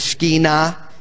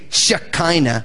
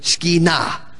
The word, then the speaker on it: Shekinah: If you were speaking Hebrew, it would be the Shekinah. In Hebrew, the accent's always on the last syllable. Okay? So we say Shekinah, because that's the way we speak English. In Hebrew, it's Shekinah. That's the way they would say it.